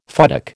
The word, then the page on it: futtock: American English